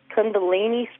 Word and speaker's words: Kundalini: I was wondering if you could tell me anything about the, I don't know if I'm saying it right or not, or pronouncing it right, Kundalini spirit? – Yeah, you did a good job.